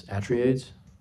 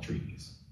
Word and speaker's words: Atreides: Atreides. That's how you say it? Okay. – That's what they say in them.